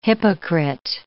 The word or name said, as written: hypocrite